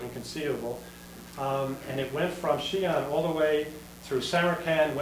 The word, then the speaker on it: Xi'an: If you have an X in Chinese, it's pronounced but it's Xi'an.